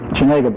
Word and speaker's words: Chenega Bay: I think the last hiring was in Chenega Bay. Is that the way you pronounce it? – Chenega Bay.